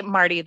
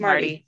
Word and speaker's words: Marti: I'm saying that right, Martilyn? – Yeah. Just say Marti, though. This is Marti.